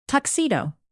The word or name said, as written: tuxedo